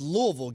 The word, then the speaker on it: Louisville: Yeah, it's Louisville. Get it Louisville. Right. That's why I know Louisville. You went to damn SC. Can't even say the name right.